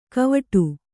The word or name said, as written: ಕವಟು